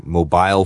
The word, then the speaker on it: mobile: So in any case, 不管是 mobile 还是 mobile, the stress should be on the M. – On the first syllable, right.